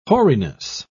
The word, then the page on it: hoariness: British English